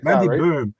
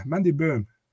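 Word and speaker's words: Mandy Böhm: So, I'm 100% right because I'm actually no German and I've seen German shows where she fought and how they pronounce it and how they interview her. Like Mandy Boom. Yeah, Mandy Boom.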